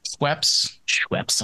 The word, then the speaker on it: Schweppes: It was made with the Schweppes.